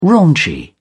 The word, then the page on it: raunchy: British English